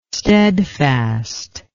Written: stedfast

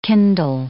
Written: kindle

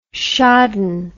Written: shodden

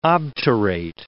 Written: obturate

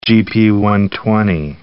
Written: gp120

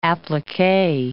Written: applique

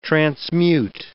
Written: transmute

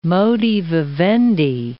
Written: modi vivendi

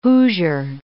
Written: Hoosier

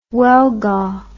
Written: huelga

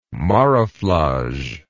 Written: marouflage

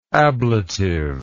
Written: ablatival

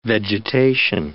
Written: vegetation

